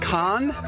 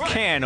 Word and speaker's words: Can: They looked at me and were like, "Do you mean like con?" And I said, which we pronounce can over here in America.